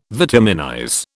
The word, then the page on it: vitaminize: British English